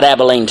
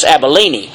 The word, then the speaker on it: Abilene: This place is what's called, it's not Abilene, Texas, it's Abilene is the way it's pronounced.